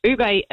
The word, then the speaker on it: ube: ube, I think it is pronounced.